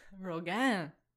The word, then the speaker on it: Rogen: Oh, je suis, Seth Rogen. Folks. – Rogen. Beautiful pronunciation.